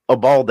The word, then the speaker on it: Uvalde: Well, I think the ones for the Uvalde should be, or is that how you say it? – Uvalde, yeah.